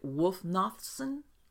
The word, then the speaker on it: Wulfnothsson: Is it Wulfnothsson? – Yes.